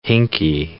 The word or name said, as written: hinky